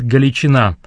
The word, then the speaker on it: Галичина: Сегодня очень часто ее именуют «Галичина» с ударением на последнем слоге, что более соответствует украинизированному названию дивизии.